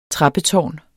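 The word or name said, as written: trappetårn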